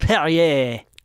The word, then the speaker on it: Perrier: I pronounce it Perrier. Perrier. – I don't even want to dignify what that sounds like, but like, because it's French water. It's French mineral water. So I think it's pronounced Perrier.